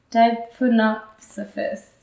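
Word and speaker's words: Deipnosophists: The Dinosophists. Dinosophists? The P has to be silent. It has to be a silent P. Dipnosophists.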